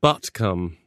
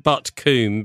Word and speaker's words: Butcombe: You don't pronounce it as Coombe when you're in the West Country. No. I was told this. No, I'd always said Butt Coombe, being, you know, nicely brought up. But no, apparently Coombe is produced as cum.